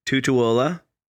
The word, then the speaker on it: Tutuola: So, your choice this time, I think I'm pronouncing it right. Tutuola.